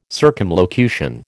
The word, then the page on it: circumlocution: American English